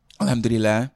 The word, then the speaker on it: Alhamdulillah: Alhamdulillah.